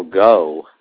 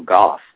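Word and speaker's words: Gough: For years I was constantly pronouncing it as Michael Goh, even though it's G-O-U-G-H. But I thought the GH was silent, like fru or something like that. But then somebody said, no, it's Michael Goff.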